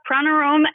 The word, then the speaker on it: Pranaram: Yeah, Pranaram actually is how it's pronounced, though you can say it however you like.